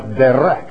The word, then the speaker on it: derek: It's D-E-R-E-K, spelled in the Hebrew. It is pronounced derek, derek.